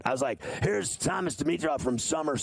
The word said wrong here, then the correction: Sumer: Sports. And you're like, it's Sumer, idiot.